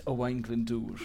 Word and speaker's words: Owain Glyndŵr: Owain Gwyndor. – it's Owain Glyndŵr.